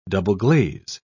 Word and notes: double-glaze: British English

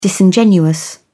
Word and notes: disingenuous: British English

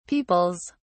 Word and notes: peoples: British English

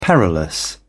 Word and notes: perilous: British English

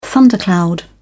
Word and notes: thundercloud: British English

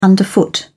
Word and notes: underfoot: British English

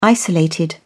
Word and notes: isolated: British English

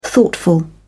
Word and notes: thoughtful: British English

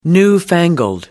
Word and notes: newfangled: American English

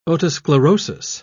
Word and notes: otosclerosis: British English

